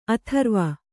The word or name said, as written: ಅಧ್ರುವ